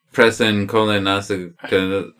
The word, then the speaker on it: Prisencolinensinainciusol: And it is called Presen Colinascolin. All right, it's PRISENCOLINESINAINCIUSOL. – Let me see it, because you're trying to pronounce it like it's Italian, but it's supposed to sound American, English.